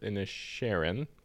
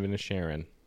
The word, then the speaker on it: Inisherin: Banshees of Inisherin, pronounced correctly. – I thought it was Sheeran, like Ed Sheeran. – Banshees of Inisherin.